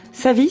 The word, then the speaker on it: Savis: Profil grec est au 7 de la rue Savis, c'est comme ça qu'on prononce. – Savis, qui était le nom de Belleville, en fait, juste avant.